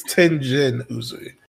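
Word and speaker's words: Tengen Uzui: Why was I thinking Tenzin? That's not his name. But yes. – That's, I don't know, it's the, like, it's Tenjin Uzui. – Tenjin Uzui. Okay. Okay. – Mm-hmm. If I'm saying that right, who knows? – Yeah.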